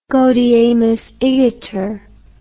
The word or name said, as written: gaudeamus igitur